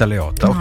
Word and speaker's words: Leotta: Perché comunque alla fine sia lei che la Diletta Diotta pronunciamola bene, perché se hai Damiano Davidi dei Maneskin è sexy, io mi offendo se tu mi parli male di Diletta Leotta.